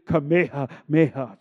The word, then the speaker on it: Kamehameha: You know, her son, Key, was going to Kamehameha at the time. You think Kamehameha, well, you know, let's say it correctly, Kamehameha, just for all my Hawaiian brothers.